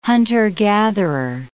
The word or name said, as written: hunter-gatherer